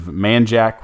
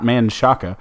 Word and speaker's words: Manjack: Joseph Manjack, which is a fantastic name. Not Manshaka, pronounced that way. It's actually spelled Manjack, M-A-N-J-A-C-K, has 29 catches for 350 yards, three TDs.